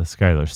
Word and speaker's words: Schuyler: Philippa So, or maybe it's pronounced Sue, is an American actress and singer, best known for Hamilton, playing Eliza Schuyler Skyler. That's the Skyler sisters.